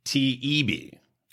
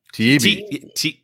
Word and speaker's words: Taibbi: but Matt Taibbi. Is that how you say his name? – People keep correcting me. Taibbi. – Taibbi. – Taibbi. Yeah.